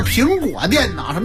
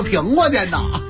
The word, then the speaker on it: 苹果电脑: 一等奖是个苹果电脑。 – 你这个发音一直都有问题，想学北京人说话没学好。那叫苹果电脑，什么叫苹果电脑？